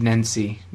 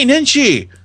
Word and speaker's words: Nenshi: Okay, so one person at replies, how do you pronounce this man's name? Nahid? – I don't know, but Nenshi? I'd say Nahid Nenshi.